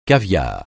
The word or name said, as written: caviar